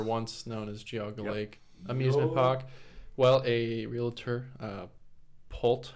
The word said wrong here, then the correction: Pulte: Homes, I don't know how to pronounce it. Pulte Homes, there you go.